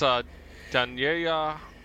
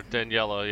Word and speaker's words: Daniela: Yes, it's Daniela, or Daniela, yeah, because it's in English. Pineda.